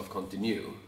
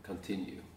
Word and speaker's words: continue: Eh, men eh, då visste jag inte hur det uttalades. Så man sa continue. Mm. Eh, Välj continue. Mm. Och, man, och sen men så förstod man ju att det här det betyder fortsätt. – Mm. – och uttalas continue.